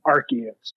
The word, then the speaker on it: Arceus: My number 3 is Pokemon Legends of Arceus. And that is the correct way to pronounce it. So you're wrong if you say it any other way.